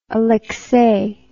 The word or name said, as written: aleksei